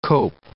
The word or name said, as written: cope